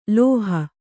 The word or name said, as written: ಲೌಹ